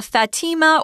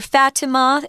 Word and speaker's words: Fatima: So we've got uh, Fatima or Fatima. It's pronounced both ways, it depends on what country you live in.